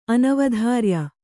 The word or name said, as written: ಅನವಧಾರ್ಯ